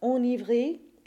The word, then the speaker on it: enivré: The word enivré is special. In this case, that initial E becomes the A nasal, but the N is still pronounced.